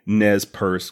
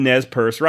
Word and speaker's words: Nez Perce: All I need to know is if I'm pronouncing Nez Perce correctly. She replied again, saying that yes, I was.